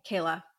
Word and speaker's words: Kayla: like Kalia? – Kayla. – I know I said that wrong.